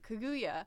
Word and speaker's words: Kaguya: Okay, I keep almost saying it wrong, but Kaguya has call her Rabbit Lady.